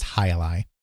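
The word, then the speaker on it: jai alai: It's J-A-I hyphen A-L-A-I, but it's pronounced Hi-Li. So they added the squash game as Hi-Li, released it as the Telstar Alpha, and released it for $40.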